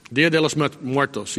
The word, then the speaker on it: Día de los Muertos: Día de los Muertos, yes, the Day of the Dead.